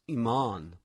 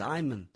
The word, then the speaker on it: Iman: And you know how she, you know, I believe her name is pronounced Iman, but my mother with her kind of Boston accent says Iman.